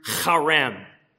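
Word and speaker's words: charem (חרם): What's actually just one Hebrew word, the verb that's used there in verse 17, it's a form of the Hebrew word charem. You know you're saying it right if you spit a little.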